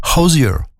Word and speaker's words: Hozier: Alors, ça s'écrit H-O-Z-I-E-R. Ça se prononce pas du tout comme ça dans son pays, évidemment, c'est Ozier.